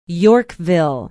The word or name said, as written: yorkville